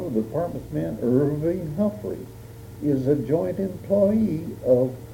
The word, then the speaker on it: Humphrey: Humphrey. – Humphrey. H-U-M-P-H-R-E-Y. – He pronounces it Humphrey. – H-U-M-P-H-R-E-Y. – But the H is silent. – Yeah, but I'm calling the dictating machine now for the transcriber. – H-U-M-P-H-R-Y.